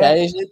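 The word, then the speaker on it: Szczęsny: Chesney, tá bom, né? Aí tá certo. Essa é a pronúncia dele em polonês.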